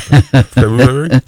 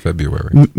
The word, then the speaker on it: February: In the United States, the most common pronunciation of the word is February. – That's what I said, February. – Merriam-Webster and American Heritage Dictionaries consider this common pronunciation correct, along with the less common but more traditional standard February, which is how I pronounce it. And, okay, the acceptance of February irritates fans of the traditional standard.